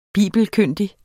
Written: bibelkyndig

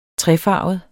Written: trefarvet

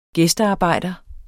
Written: gæstearbejder

